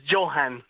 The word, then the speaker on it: Johan: Yes, Johan, like John, but it's fine.